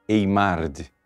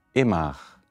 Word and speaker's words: Eymard: Geralmente se pronuncia em português Eymard, não é? Mas é um sobrenome francês, Eymard.